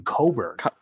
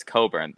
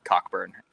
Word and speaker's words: Cockburn: Apparently, you pronounce his last name Coburn. – Yes, I was about to bring that up. Yeah, it's Coburn. Yeah, I'm gonna guess. Somewhere along the line, someone just didn't want it. One of the family members just got tired of being called Cockburn and decided to just say, you know, but in middle school, no, the CK is silent.